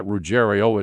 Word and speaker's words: Ruggiero: Now, Ruggiero was spelled R-U-G-G-I-E-R-O, but everybody pronounced, including Reggie and his family, pronounced it Ruggiero.